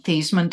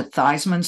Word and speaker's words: Theismann: Notre Dame's PR guy said, hey, how about you change the pronunciation of your name from Thiesman to Theismann so that it rhymes with Heisman and you might have a better chance?